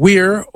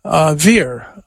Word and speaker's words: vir: Like you can pronounce the word V-I-R, which means man, weir, or veer. I use the Italianate or the church pronunciation veer.